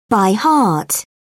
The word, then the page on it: by heart: British English